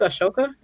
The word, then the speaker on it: Ahsoka: Yeah, but I did watch the animation one where uh, Ahsoka, Ahsoka, that's how you say it.